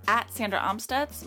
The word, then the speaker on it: Amstutz: And you can find me at Sandra Amstutz.